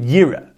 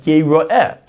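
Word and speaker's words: Yeroeh (יראה): Now, it could, the way it's written, you would read it Yira, because that's the more simple, straightforward pronunciation of the word, Yud Resh Aleph Hei. Yira, that should see. Now, at the same time, it's pronounced Yeroeh, should be seen.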